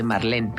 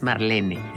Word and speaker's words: Marlene: como Marlene Dietrich, por ejemplo, que todo el mundo dice Marlene, pero es Marlene.